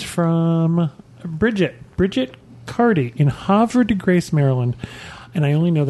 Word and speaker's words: Havre de Grace: and they have the Havre de Grace Decoy Museum.